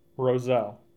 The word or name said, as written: Roselle